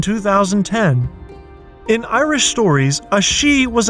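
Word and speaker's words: sídhe: The plural of she is she, pronounced the same, but spelled S-I, often with an acute accent, D-H-E.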